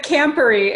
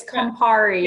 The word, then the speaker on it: Campari: Yeah, you said Campari. It's Campari. – Yeah. – There you go. – I have visuals for you. – Oh, I love it. – Yeah. – Campari. – Campari.